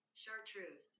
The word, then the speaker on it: chartreuse: Let's see. Chartreuse. I was saying it right. Chartreuse.